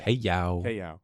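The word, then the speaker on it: Kay Yow: Kay Yao? Am I saying that right? I'm saying that right. – Kay Yao.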